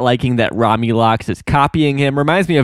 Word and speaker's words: Romulox: And interesting here how Dana Snyder keeps swapping between Romulox and Romulux, like L-U-X versus L-O-X. It's spelled L-O-X, so I assume it's Romulox.